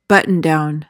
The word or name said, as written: button-down